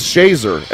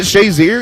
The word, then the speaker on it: Shazier: Initially they said it's pronounced Shazer. And I was like, I asked his dad. I'm like, isn't it Shazier? And he said, yes.